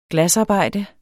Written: glasarbejder

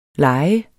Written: lejer

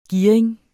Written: gearing

